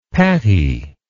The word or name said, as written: paty